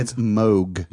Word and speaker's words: Moog: It's Moog.